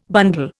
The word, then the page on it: bundle: Indian